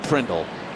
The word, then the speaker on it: Prindle: So Scott Prindle rhymes with Grindle.